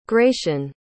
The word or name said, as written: gratian